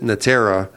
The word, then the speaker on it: Natera: So a lot has happened since 1993 when I started getting involved in WorkNet and building that organization, which today is called Natera, the Natera Alliance. We pronounce it Natera. It's spelled like Net Era.